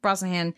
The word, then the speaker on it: Brosnahan: But I will say for me, well, Rachel Brosnahan, did I say that right? – Yes. I think so, yeah.